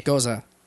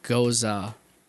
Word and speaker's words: Gose: Yes, it's spelled G-O-S-E, but it's pronounced Goza. – With interesting. – That, at the, yeah, I found that out after okay. – saying goes for way too long.